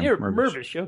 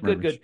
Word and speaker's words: Mirvish: Close enough. Mirvish. Yeah, Dan. – Mirvish. Okay, good, Mirvish.